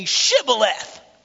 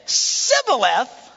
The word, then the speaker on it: shibboleth: And if he replied no, they said, well, all right, well, then say shibboleth. Say it. Just say it. And it says, if they said Sibboleth, because he could not pronounce the word correctly, they seized him and killed him. 42,000 Ephraimites were killed doing this. That's a lot of dead people. Because they couldn't pronounce their SHs.